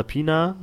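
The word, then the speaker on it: Peña: Gerade äh, Michael Pena oder Pina, wie er ausgesprochen wird, der mit, seinen, mit seiner lustigen Weise Sachen nachzuerzählen.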